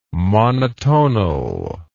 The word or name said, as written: monotonal